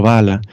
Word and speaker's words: Valla: En italiano se dice Lorenzo Valla, pero supongo en español es Valla porque es con L de caballo.